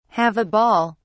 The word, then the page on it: have a ball: British English